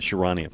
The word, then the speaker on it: Charania: By the way, nice pronunciation on the Shams part. That's on the Charania part.